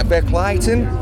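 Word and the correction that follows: Verkligheten: earlier this year. – Verkleiheten. Yes.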